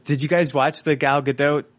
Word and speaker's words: Gal Gadot: It's pronounced Gal Gadot.